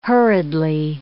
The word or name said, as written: hurriedly